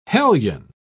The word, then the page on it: hellion: British English